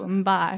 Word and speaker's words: Mbye: with my mum and she goes, How do you say that last name? Very simple, Mbye. – Mbye. Mm, it's like It's like mbop.